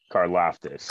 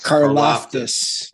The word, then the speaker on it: Karlaftis: Um, and you got to correct me on his name because you said it right the other night, and I I didn't. But the DN from Purdue, George Carl Carl Fadis. Karlaftis. – Karlaftis.